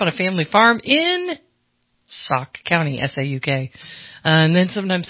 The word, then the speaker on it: Sauk: How do you say it, Bill? What's your name? – Sauk. – I think it's Sauk.